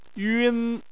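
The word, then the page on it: yun: tone 1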